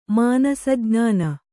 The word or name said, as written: ಮಾನಸಜ್ಞಾನ